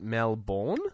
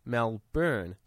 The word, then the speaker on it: Melbourne: In third place is Melbourne. So apparently a lot of people pronounce it Melbourne. – Yeah, Melbourne. – And this is what the article says. It's actually called Melbourne, like B-U-R-N. – Melbourne. – Yeah, like you got burnt. Burnt. – Melbourne. No, I say Melbourne. – Yeah, B-I-N, right? – Yeah, Melbourne.